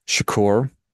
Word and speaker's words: shukr: It's S-H-U-K-R, shukr. Apologize for my pronunciation.